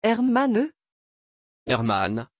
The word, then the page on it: herrmann: French